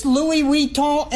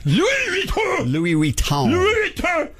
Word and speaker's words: Louis Vuitton: I don't know you're pronouncing it right. Let's let our friend from CNN, Jenny, pronounce it for us. – This Louis Vuitton airplane bag sells... – There you go. That's how you say it.